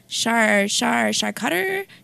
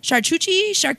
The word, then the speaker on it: charcuterie: And he's like, char-cutter, charcuterie. Like, so you just call it charcuterie.